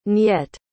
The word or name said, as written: nyet